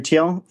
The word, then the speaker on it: Thiel: Peter Thiel for a position.